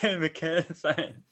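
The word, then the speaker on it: McElhenney: And the fact that like the number that's been touted around is that him and I can never pronounce his name, Rob McKennelly. – Yeah, McKennelly.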